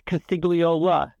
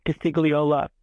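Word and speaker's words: Costigliola: My name is even longer than it seems. It's Castigliola. There's an extra syllable in there. Castigliola, as I pronounce it.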